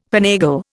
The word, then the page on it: finagle: Indian